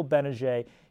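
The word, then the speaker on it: Gbinije: But how about Silent G, Michael Benajay?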